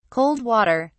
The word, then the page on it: cold water: British English